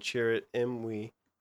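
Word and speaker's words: Chirrut Îmwe: And this follows Baze Malbus and Chirrut Imwe, how you say it? – I think you got it right.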